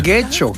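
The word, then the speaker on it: Getxo: Que no, Guecho, no, Guecho. – Guecho. Exacto, ahí es donde me equivoco yo, que lo vuelvo U.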